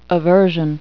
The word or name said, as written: aversion